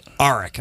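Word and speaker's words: Aric: Because when I first moved to Michigan many years ago, people would mispronounce my... They would say Arik. I'm like, no.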